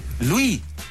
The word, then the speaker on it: Louis: Mira, es Luis. – Luis. – Luis, se escribe Louis.